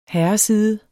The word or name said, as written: herreside